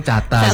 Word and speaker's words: Cata.: Tapi dibaca enggak titiknya? – Kalau dalam pelafalan ya kita cukup bilangnya teman-teman Cata. Kalau Cata titik, Cata titik. – Enggak sih, ya kita cuma Cata aja.